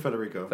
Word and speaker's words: Federico: How do you pronounce his first name? Federico? – It's Federico.